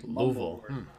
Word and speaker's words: Louisville: But down here, it's Louisville. – Really? – Yeah, it's like a one syllable like Louisville.